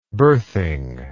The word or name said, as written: birthing